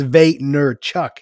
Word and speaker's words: Vaynerchuk: It's Vaynerchuk is that's how he pronounces it sometimes.